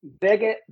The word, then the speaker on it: Vivek: I'm not sure I'm pronouncing his name right. – Vivek, Vivek V I V E K.